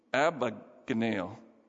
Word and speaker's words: Abagnale: Ugh, I butchered his name. Abagnale.